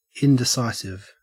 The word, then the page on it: indecisive: Australian English